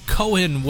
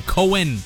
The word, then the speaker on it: Cohen: The legendary Leonard Cohen would have turned... Why did I say Cohen? Cohen. Said it wrong.